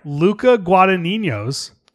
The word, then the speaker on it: Luca Guadagnino: Luca Guadagnino's.